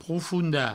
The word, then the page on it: profondar: proufounda; provençal